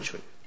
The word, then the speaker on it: Cui: Chui. Tanhui Chui. Yes. Yeah. Okay. C-U-I. – That's what I'm Chui.